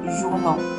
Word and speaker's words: journaux: 它的复数形式通常情况下会有两种，一种呢，我们把 r l 去掉，换成 r u x，比如说 journal，journal 复数 journal，un journal，des journaux，这个呢也是唯一把单数变复数在发音上有变化的啊，以 r l 结尾的名词要变成 r u x。